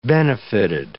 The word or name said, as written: benefited